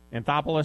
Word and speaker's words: Anthopoulos: Yeah, Anthopoulos said, is that saying this right, his name right? Anthopoulos, is that how you say it? – Close enough.